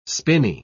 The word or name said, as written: spinney